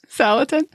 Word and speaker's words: Salatin: This is so much fun, and just makes me want to pick up a bunch of books by Joel Salatin. Is that how you say it? – Yes. – Salatin.